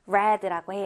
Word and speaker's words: read: 하나 주의해야 할 것이 read, 읽다의 과거형은 철자는 똑같이 적어주는데 발음이 달라집니다. read라고 해요. 읽었다, read.